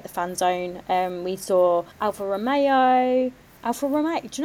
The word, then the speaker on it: Alfa Romeo: Did I say that right? I didn't say Romero, did I? – No. – No. – No, you didn't.